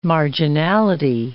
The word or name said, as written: marginality